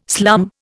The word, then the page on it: slum: Indian